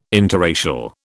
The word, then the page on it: interracial: British English